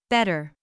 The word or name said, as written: better